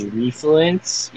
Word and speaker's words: Refluence: My name is Refluence. You can pronounce it any other way, but it's R-E-F-L-U-E-N-C-E.